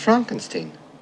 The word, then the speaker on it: Frankenstein: No, it's pronounced Frankenstein. – Do you also say Frederick? – No, Frederick. – Well, why isn't it Frederick Frankenstein? – It isn't, it's Frederick Frankenstein.